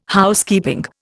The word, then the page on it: Housekeeping: Indian